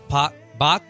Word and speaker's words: Pak: It's in Korean. It's pronounced Bak, but like it's...